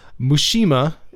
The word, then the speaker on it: Muxima: But the first track that we're going to listen to of Waldemar Bastos is Mushima. It looks like it's spelled Muxima, but it's pronounced Mushima.